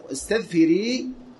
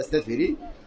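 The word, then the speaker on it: استثفري: نعم، فقال لها استذفري هكذا بهذا النطق، يعني نطقها يعني بسين المهملة فمثنى فوقية فمثلثة فراء، يعني هكذا استذفري، وهو كما قلنا.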